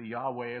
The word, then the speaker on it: Yahweh: And this is the name by which he will be called, the Lord, or the YHVH, the Yahweh, as we believe it's pronounced, our the Lord, Yahweh, our righteousness.